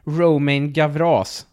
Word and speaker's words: Romain Gavras: Av en herre som heter Roman Gavra. Eller Romain Gavras, som man kan också uttala det som.